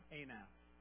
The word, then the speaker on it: Anath: After him was Shamgar, the son of Anath. Sorry, I'm Shamgar. Shamgar means sword in Hebrew. And Anath, it actually is pronounced Anat, A-N-A-T.